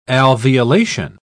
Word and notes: alveolation: British English

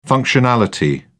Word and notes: functionality: British English

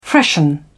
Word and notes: freshen: British English

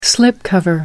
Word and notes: slipcover: American English

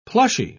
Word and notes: plushy: British English

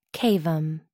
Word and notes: cavum: American English

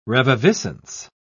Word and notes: reviviscence: British English

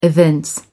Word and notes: evince: American English

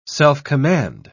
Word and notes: self-command: British English

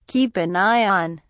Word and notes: keep an eye on: British English

